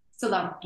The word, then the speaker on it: Salome: The new book, Birth Keeper of Bethlehem, from Womancraft Publishing, which tells the fictional story of, is it Salome? Am I saying that right? Yeah. Salome, okay.